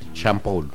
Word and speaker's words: Sean Paul: Sean Paul, ¿cómo se dice? – Sean Paul.